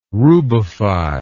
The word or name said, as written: rubify